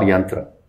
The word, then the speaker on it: Yaantra: Is it Yaantra or Yaantra? – It's Yaantra. – Yaantra. But spelled with a double A.